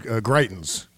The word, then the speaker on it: Greitens: I'm going to go with your pronunciation, which is Greitens. We're going to go with Greitens on the show.